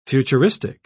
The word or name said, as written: futuristic